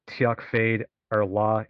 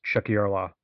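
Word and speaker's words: Tiocfaidh ár lá: And then it goes into Chuck Yarla, which is, for those that are wondering how I just pronounce that, it's Gaelic. When you look at it, it doesn't look like what it's spelled like is now it's pronounced. It looks like if you're looking at it phonetically, like it would say Tiak Fade Arla, it's Chuck Yarla is the best way I can think to pronounce it to people that don't speak it.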